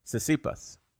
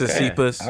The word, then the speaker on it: Tsitsipas: Tsitsipas. Okay. Tsitsipas.